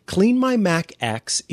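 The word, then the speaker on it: CleanMyMac X: And yes, it is pronounced X as opposed to 10. Clean My Mac X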